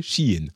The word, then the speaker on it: Shein: évidemment, bon, ce c'est pas Shine, c'est pas toutes les prononciations, c'est Shein en fait.